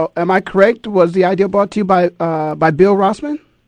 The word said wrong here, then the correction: Rasmussen: Am I pronouncing that correctly? – Yeah, Bill Rasmussen was a fellow, and his brother, his son Scott.